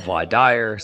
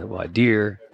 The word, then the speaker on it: voir dire: Am I saying that correctly? Voir dire. It's a French word. – Well, it depends where you're at, but some places call it voir dire, some places voir dire.